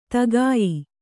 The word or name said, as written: ತಗಾಯಿ